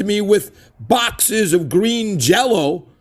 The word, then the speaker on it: Jellÿ: Yes, but it's still, yeah, it's spelled Jellÿ, but it's pronounced Jell-O still because there's those umlauts above the Y.